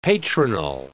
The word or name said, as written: patronal